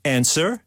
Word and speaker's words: answer: Silent P, right? Or answer? – W. – W yeah.